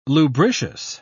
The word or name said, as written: lubricious